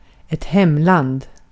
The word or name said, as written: hemland